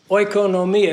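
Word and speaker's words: oikonomia (οἰκονομία): Oikonomia, if I say it the way it would be pronounced by a true Greek, oikonomia.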